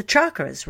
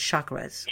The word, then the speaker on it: chakras: like the chakras, right? – Yes. – I said it wrong. It's chakras.